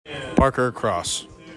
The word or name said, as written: PARKER CROSS